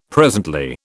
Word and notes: presently: British English

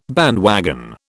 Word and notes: bandwagon: British English

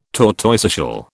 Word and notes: tortoiseshell: British English

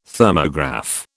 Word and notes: Thermograph: British English

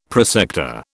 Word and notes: prosector: British English

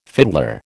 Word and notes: fiddler: American English

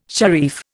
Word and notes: sharif: Indian

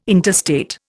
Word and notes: interstate: Indian

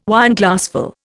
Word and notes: Wineglassful: Indian

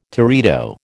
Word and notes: teredo: American English

